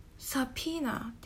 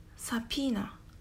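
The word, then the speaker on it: subpoena: P-O-E-N-A でサピーナ B を発音しませんさらにとポエというところを P というふうに発音しますサピーナ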